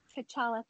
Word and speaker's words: T'Challa: T'Challa, thank you.